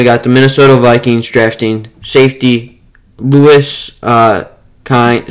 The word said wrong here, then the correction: Cine: ah, see, I don't really know how to say this guy's name, but Lewis Cine from Georgia.